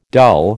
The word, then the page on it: dull: American English